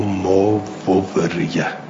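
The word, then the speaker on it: ما وُورِيَ: اون جلسه قبلی هم تأکید کردم که این لیوبدی لهما ما ووریه نه ما ووریه. این کلمه رو با یه دونه واو می نویسند اما با دو تا واو تلفظ میکنند. ماوریه نوشته میشه بلی، ماووریه خونده میشه.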